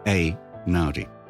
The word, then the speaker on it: Einaudi: It's also how the entire English-speaking world ended up mispronouncing his bloody name. – It was only many years later when Ludovico came to Britain that I realised the true pronunciation. If it's E-I in Italian, it should be Einaudi.